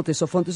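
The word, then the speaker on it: Ctesifonte: Sí, esa ciudad la van a conocer como Tesofonte, se escribe con, con C y con T, le decimos Tesofonte, que en griego significa lleno de sonido.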